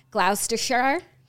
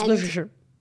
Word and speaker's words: Gloucestershire: In his book, he talks about how an older English woman took his virginity in, I'm going to pronounce this wrong, just leave me alone, Gloucestershire. – No, Jackie, it's Gloucestershire.